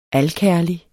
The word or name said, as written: alkærlig